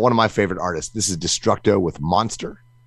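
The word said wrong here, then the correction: MxNxSxTxR: So Minister, not Monster. Minister.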